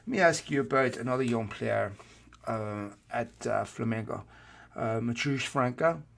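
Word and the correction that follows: Matheus França: Am I saying that right? – Yeah, so that's Mateus França.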